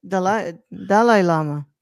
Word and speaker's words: Dalai Lama: We're just going to say Dalai Lama and just go with it. – That's how you actually pronounce it, though, Brad. Dalai Lama.